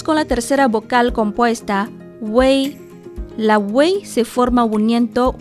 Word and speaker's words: wei: Se pronuncia wei, wei como si hubiera una E entre la U y la I, en otras palabras, como una combinación de la vocal simple U y la compuesta E, Wei. De este modo, pronunciamos primero la U y luego cambiamos la forma de la boca para lograr la E.